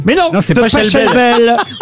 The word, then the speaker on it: Pachelbel: Ariel, que vous ayez dit Pachelbel.